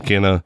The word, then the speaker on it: Kena: So just wanted to give you my final thoughts on Kena, as you know, that's actually how you pronounce the game. I know a lot of people, you know, it's like, is it Kena or Kena or how do you pronounce it? But in the actual gameplay itself, you hear, you know, other characters, you know, acknowledge her as Kena.